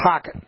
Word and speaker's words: Hocatt: H-O-C-A-T-T, pronounced Hockett.